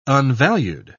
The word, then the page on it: unvalued: British English